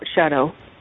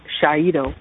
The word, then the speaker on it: Shaido: And, I mean, he just gave them the look and said, it's not Shaido, it's not Shadow, it's Shaido.